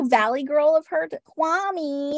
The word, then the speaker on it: Kwame: And there was a lot of drama with Kwame. So the correct pronunciation, the Ghanaian pronunciation of his name is Kwame. – Okay. – But for some reason, him and the entire cast insisted that it was Kwame.